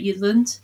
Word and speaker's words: Jutland: Jutland. You didn't really hear the T in it.